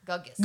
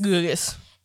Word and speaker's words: Gugus: G-U-G-U-S. Guggus. – Guggus.